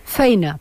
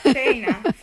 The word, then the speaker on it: feina: Feina, quasi. – Feina. – Feina, molt feina.